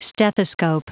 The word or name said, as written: stethoscope